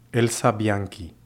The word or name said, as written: Elsa Bianchi